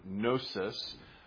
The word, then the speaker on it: gnosis: Now, in Greek, knowledge is the word gnosis. That has a silent G on the front, though, which is where you get the term Gnosticism from, this this fascination with this special secret knowledge.